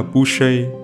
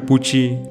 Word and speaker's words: Boucher: Ngoài ra, tên họ của Christopher được phát âm là Boucher, chứ không phải Pucci như Anthony đã nói.